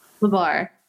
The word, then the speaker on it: LeBar: I think that you're in a relationship with Ryan Lieber. Is that correct? – LeBar. Yeah. – LeBar. Ooh, thank you for correcting my pronunciation.